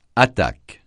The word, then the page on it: attaque: francais